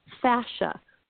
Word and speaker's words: fascia: or the more sciencey word for that is fascia, like fashion, but it's spelled F as in Frank, A-S-C-I-A, fascia. Not to be confused with fascia or facial.